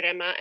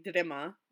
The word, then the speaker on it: Rima: But yeah, oh, we forgot to mention, yeah, so our names are pronounced Rima.